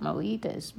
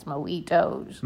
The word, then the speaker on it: mojitos: I always pronounced it mojitas, but it's mojitos.